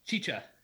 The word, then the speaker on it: Chicha: How do you pronounce that? Chica? – Chicha. – Chicha. Ooh.